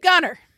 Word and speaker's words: Gunter: Well, I guess in the Cajun way, they're like, Steve Gunner. – Yeah, that's exactly what it is. – So we're going to say Gunner, but it's spelled like Gunter. I have to throw that T in there.